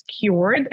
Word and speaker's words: kyoord: Social media or website, it's the same, it's Kyord, and it's spelled K-Y-O-O-R-D. – Cool. – Which is the phonetic spelling of the word Kyord.